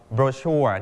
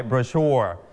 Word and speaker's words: brochure: คำนี้พิเศษมากนะครับคำที่เรารู้จักคำว่าโบชัวเนี่ยคนอเมริกันนะครับอังกฤษแบบอเมริกันจะอ่านว่า brochure เน้นที่พยางค์ที่ 2สีชมพูที่ครูเน้นไว้ brochure แต่คนอังกฤษเขาจะเน้นที่พยางค์แรกนะครับต่างกันเขาจะอ่านว่า brochure brochure brochure